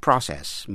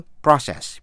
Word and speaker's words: process: A complicated process, một quá trình phức tạp. Có hai cách đọc chữ process, P-R-O-C-E-S-S, process, đọc theo tiếng Mỹ, process, tiếng Anh, process.